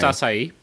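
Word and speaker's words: açaí: I got my acai. I got my blackberry. – It is pronounced acai.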